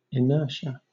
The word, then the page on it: inertia: British English